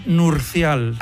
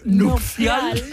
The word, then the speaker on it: nupcial: Sí, es que yo digo que hasta los 13 años pensé que era nurcial. – Nurcial. – ¿Ah, sí? – Y claro, me cuesta decir nupcial, que lo he dicho mal.